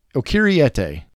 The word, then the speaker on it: Okieriete: It's quite a mouthful. It's actually not that hard to say. I'm terrible with names, but it's O'Keeriete.